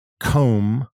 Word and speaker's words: comb: So again, thumb, T-H-U-M-B, comb, which is es un peine, plumber, un plomero, and climb, o subir, trepar. So the B in these words is silent for some reason. And someone reading these words may say thumb or comb, and that's not what we say.